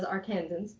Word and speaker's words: Arkansans: compared with other Arkansans, which is apparently the right way to say that. – The demonym. It's a word I know from Wikipedia. – That's awesome. The demonym for people from Arkansas is Arkansans.